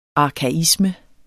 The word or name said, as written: arkaisme